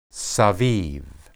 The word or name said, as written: sebib